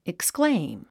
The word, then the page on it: exclaim: ekskléim; エクスクレイム